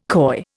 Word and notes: coy: Indian

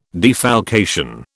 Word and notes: defalcation: British English

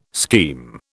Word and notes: scheme: British English

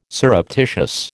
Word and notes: surreptitious: American English

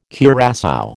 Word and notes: curassow: American English

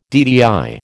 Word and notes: DTI: American English

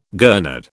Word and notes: gurnard: British English

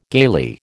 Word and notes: gaily: American English

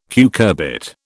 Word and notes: cucurbit: British English

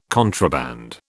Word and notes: contraband: British English

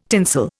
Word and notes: tinsel: Indian

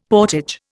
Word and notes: portage: Indian

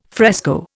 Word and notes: fresco: Indian

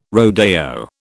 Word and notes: rodeo: British English

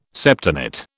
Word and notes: septenate: British English

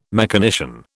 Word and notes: mechanician: British English

